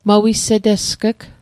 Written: mawisetesg'g

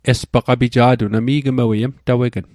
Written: mtawegn

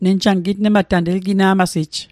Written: gitnmat

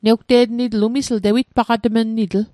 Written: tewitpaqatg

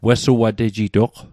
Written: wesuatejitoq

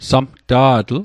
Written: samta'tl

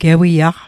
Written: gewiaq

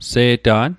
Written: Se'ta'n

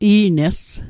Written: i'nes

